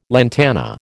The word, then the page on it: lantana: American English